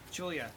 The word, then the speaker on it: Julia: A lot of people pronounce Julia with just two syllables. Okay, let me see if I can do it. It's really hard. It's, no. – Yeah, I say it with three.